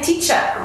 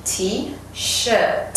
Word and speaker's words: T-shirt: Really careful with the pronunciation of T-shirt. The amount of people I hear say, "I'm wearing a T-shirt." I'm like, "Are you sure about that?" So yeah, careful with that pronunciation. T-shirt. – Mm. – And that T at the end.